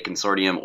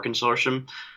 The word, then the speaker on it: consortium: I think I actually looked it up, and it's one of those weird English words where both pronunciations are acceptable. So you can say Consortium or Consortium.